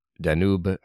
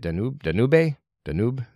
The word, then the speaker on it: Danube: And he also argues that from the Moscow side, Moscow might start escalating by targeting Ukraine's Danube, I think that's how you say it, Danube is the E silent, the Danube Delta ports.